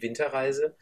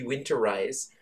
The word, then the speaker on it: Winterreise: I did a performance of Schubert's Winter's Journey, which I renamed Winter Ice, which in German is Die Winterreise. So I thought Winter Ice was actually how Americans pronounce it anyway, Die Winterreise. And it sounds like Winter Ice.